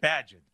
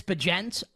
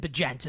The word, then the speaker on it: Bagent: Is it Bagent or is it Badgent? What's the official pronunciation we're moving forward with? – I have heard like many people say many different things. I believe it's Bagent. I'm willing to be wrong on that, but I think it's Tyson Bagent is how to pronounce his name.